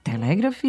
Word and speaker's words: telegraf: Mada stariji spikeri, naročito na radiju, i danas kažu telegraf i telegram. – Telegram i telegraf sa kratkouzlaznim akcentom na drugom slogu do skora su bili jedino ispravni oblici.